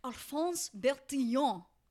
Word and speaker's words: Alphonse Bertillon: So another heavy hitter throughout this evolution, right, is this man, a Frenchman named Alphonse Bertillon.